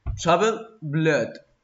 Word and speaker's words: Troubled Blood: Troubled Blood.